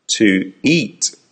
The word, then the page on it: to eat: American English